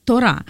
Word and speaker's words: Тора: потому что Тора, Тора, правильно произношение,